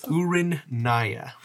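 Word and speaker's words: Urinniha: Urinia.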